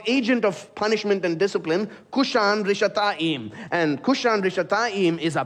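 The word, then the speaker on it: Cushan-rishathaim: In Hebrew, Mesopotamia there is the longer name is given, which is Aram Naharaim, which rhymes with his name, Cushan Rishathaim.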